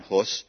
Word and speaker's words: Hus: His name was John, we would say Hus, H-U-S. It's actually pronounced Hus.